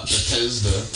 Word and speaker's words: Bethesda: I said it wrong. I know. – But that's alright. Uh, Bethesda. Bethesda. Bethesda.